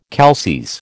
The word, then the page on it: calces: American English